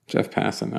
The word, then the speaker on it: Passan: Let's start with this piece of business that's just come down, and that is, according to ESPN's Jeff Passan? Am I saying this right? – Jeff Passan.